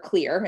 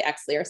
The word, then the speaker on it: Xlear: Did not use, we use Xlear, or Clear is the way proper way to say it, but a lot of people say Xlear.